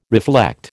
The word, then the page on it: reflect: American English